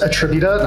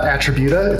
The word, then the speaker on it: Attributa: It is pronounced attributa, not attributa. It's attributa.